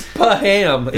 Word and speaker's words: Pham: There's ham in his name. – I don't know. It's Pham. Is how you pronounce Pham. – It.